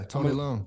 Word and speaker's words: Leung: Tony Leung? I want to say his name is? – Yeah, Tony Leung.